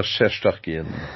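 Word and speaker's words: Shesterkin: Euh, tu rajoutes ça un Capo Caco, Artemi Panarin, euh, Adam Fox, euh, le, gardien, là, que c'est sûr que je vais mal le prononcer, qui est Tchestkoskin. – Igor Chestorkin.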